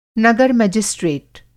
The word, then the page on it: नगर-मजिस्ट्रेट: Hindi